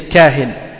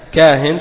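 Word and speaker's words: kahin (كاهن): Le singulier c'est kahin. Hein? Donc kuhan, kahin. Kahin ça s'écrit kaf, alif, ha.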